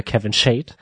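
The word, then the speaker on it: Kevin Schade: Ich finde, wir sollten an der Stelle aber auch nochmal festhalten, dass du ihn gerade falsch ausgesprochen hast, denn wie er ja selbst gesagt hat, äh, heißt er ja Kevin Shade.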